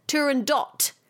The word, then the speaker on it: Turandot: Spelt Turandot, but apparently you're meant to pronounce it without the T. Discuss. That's what the internet says. – All right. I've heard both. – I've heard both too.